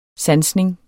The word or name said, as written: sansning